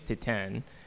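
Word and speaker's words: Titane: But the movie that I'm pulling for that played so well here and is now actually opening is Titan, which I can never pronounce right. – You did it right just now.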